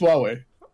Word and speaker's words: Huawei: From Hawaii. How do you pronounce it? Is it Hawaii? It looks like Hawaii. I'm just going to pronounce it Hawaii. – No. It's Huawei.